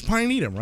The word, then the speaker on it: pinetum: P-N-E-T-U-M. P-I-N? – P-I-N. – Yeah. – E-T-U-M. Pinetum. – Pinetum. And it's Pinetum, right? – It's Pinetum, yeah. 1828. Let me see how if this sentence gives it away here. – Yeah, Pinetum.